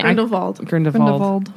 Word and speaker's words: Grindelwald: Should I say the V? We got to say it the same. How are we saying it? – Grindelwald. – Grindelwald. – Yeah. – I like to say Grindelwald.